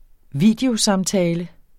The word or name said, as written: videosamtale